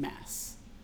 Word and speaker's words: Mads: Okay, I thought I heard at one point that he pronounces his name Mess. Mess Mikkelsen. – Really? – Yes. – He's Danish. Is that how you say it in Danish? – I think so. Because he was like Mess, like hot mess.